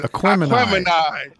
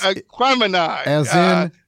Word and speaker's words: Aquemini: He told us how to pronounce it. – Aquemini. You know what? – Yes, it's Aquemini. – As in, I wonder what happened to the O'Neaters.